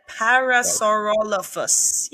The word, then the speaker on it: Parasaurolophus: I said it right. Parasaurolophus. Yes. Right.